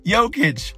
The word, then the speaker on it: Jokic: I know how to say his name. Jokic.